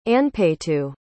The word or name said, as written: anpaytoo